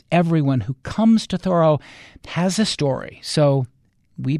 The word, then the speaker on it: Thoreau: You may say it Thoreau. That's how I usually say it. A lot of people say it that way. But apparently the folks in Concord who still know the Thoreau family pronounce it Thoreau.